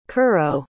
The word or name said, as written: curro